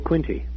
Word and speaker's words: Quinte: Is that the way you pronounce that? – That's right, the Bay of Quinte.